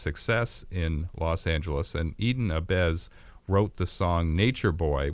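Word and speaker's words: eden ahbez: Have I pronounced it correctly? – Yes, Eden Abez was what was called a nature boy.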